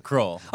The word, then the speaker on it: Kroell: Kroell.